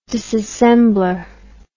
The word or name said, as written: disassembler